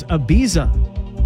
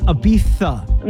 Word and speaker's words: Ibiza: Like, for example, I thought it was Ibiza, but apparently it's Ibiza. – My children tell me I've been pronouncing it wrong.